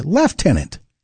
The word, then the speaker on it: Lieutenant: It's spelled C-O-L-O-N-E-L, but pronounced Colonel. And right behind it is Lieutenant. In Canada, we say Lieutenant. There's no F there.